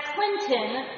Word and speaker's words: Clinton: That's Clinton, spelled like the president, but as they say there, it's where the T is silent and the T is sweet.